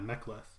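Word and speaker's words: Mek'leth: Is it called a Mekleth? – Yeah, a Mekleth.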